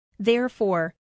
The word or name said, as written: therefore